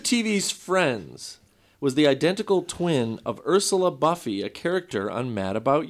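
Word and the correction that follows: Buffay: I mispronounced Buffy. It was Buffet, I believe.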